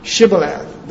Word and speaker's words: Shibboleth: These Ephraimites could not say Shibboleth. They couldn't pronounce the SH.